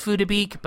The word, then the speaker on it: Phuc Dat Bich: It's pronounced Phuda Beek, but it looks a lot like fuck dat bitch.